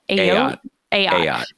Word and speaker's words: Ayotte: Ayotte.